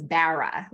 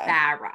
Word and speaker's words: Barra: Well, my husband's from the East Coast, so it's Barra, like a Barra. Barra.